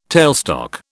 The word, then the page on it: tailstock: British English